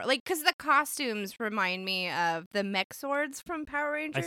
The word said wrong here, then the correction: Megazord: Is that how you say it? – Megazords? – I'm old.